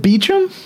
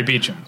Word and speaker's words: Beauchamp: But yeah, her name, any person with half a high school education who looked at that name on paper would pronounce it Beauchamp or Beauchamp. – Yes. – But she does say Beecham.